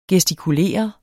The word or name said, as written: gestikulere